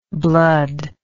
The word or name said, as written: bloodlike